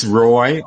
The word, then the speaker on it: Roy: It's Roy, R-O-Y, Schoeman, which is spelled S-C-H-O-E-M-A-N.